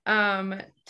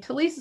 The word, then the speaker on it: Telise: Okay, and mine, you can find me on Twitter, Telise Starseeker, and Telise is spelled T-E-L-I-S-E.